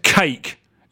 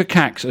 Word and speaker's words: Cack: "Where's Cake?" yelled Blodgett, repeating his mispronunciation. Ruhugu was one of Dr. Cack's assistants, perhaps the most fanatical. "It's Cack," he said, "to rhyme with snack."